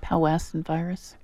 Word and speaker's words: Powassan: They can transmit all kinds of things in Wisconsin, not just in North Carolina where we're based, but anaplasmosis, babesiosis, ehrlichiosis, something called Powassan, how do you pronounce that? – Powassan virus.